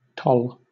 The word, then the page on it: toll: British English